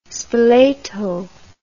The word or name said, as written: spoleto